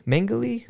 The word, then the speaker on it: Mengele: Joseph Mengele? Mengele? – Mengele. – Mengele. I don't know if I can ever say his name right.